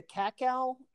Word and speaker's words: cacao: Yes, and I'll tell you, I'll look at the cacao percentage. Is that pronounced right? – Yeah, yeah.